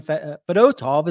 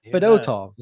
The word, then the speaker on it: Fedotov: And Ivan Fedotov is how you say his last name. Apparently, it's going to bother me because I don't like how it's pronounced. But Fedotov is how it's pronounced.